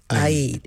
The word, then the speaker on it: Eid: I actually looked it up because, in English we say Eid, but in Arabic it's Eid.